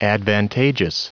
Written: advantageous

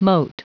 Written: moat

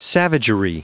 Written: savagery

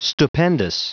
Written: stupendous

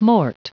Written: mort

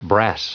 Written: brass

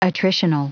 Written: attritional

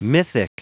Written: mythic

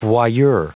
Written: voyeur

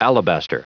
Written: alabaster

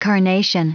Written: carnation

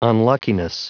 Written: unluckiness

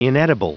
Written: inedible

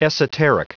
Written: esoteric

